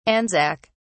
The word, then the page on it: anzac: British English